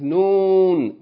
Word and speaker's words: Noon: The name of this letter is Noon. The sound of it is N, like N in English.